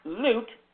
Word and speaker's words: Loupe: It's pronounced Loot. It is spelled L-O-U-P-E.